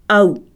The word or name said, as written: 'au